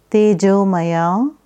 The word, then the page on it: Tejomaya: Sanskrit